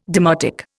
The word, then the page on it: demotic: Indian